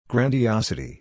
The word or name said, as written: grandiosity